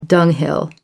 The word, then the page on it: dunghill: American English